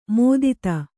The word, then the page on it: ಮೋದಿತ: mōdita